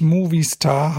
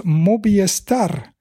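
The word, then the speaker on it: Movistar: Und wollte einen Beitrag leisten zu, jetzt weiß ich gar nicht, wie sie heißt, vom Team, was eben nicht Movie Star heißt. Das soll man zwar denken, aber das ist ja ein spanisches Unternehmen oder es ist eine Marke der Firma Telefonica und äh, auf Spanisch spricht man das aus Mobiestar. Also das V von Movie ist quasi ein B vereinfacht ausgedrückt und äh,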